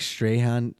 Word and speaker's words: Strachan: It probably is that Mike Strahan. It's spelled Strachan.